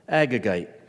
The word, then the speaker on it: Agagite: We read in verse 1, Haman was an Agagite, if that's how you pronounce it. That's how I pronounce it, Agagite.